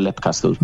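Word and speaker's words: Leap Castle: Fíjate, yo diría Lip Castle en su día, Lip. Pero como Sean Ryan lo pronuncia Lip Castle, yo me quedé con Lip Castle.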